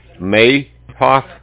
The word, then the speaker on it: Maehoff: J-A-C-K. – Okay, Jack. – Last name? I'm going to spell it for you. M-A-E-H-O-F-F. Mayhoff.